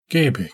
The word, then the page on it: gebeh: Galindri